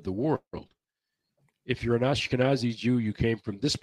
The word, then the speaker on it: Ashkenazi: There are the Anakasazi, I think, or I don't know. – Ashkenazi. – I can never pronounce that. Ashkenazi.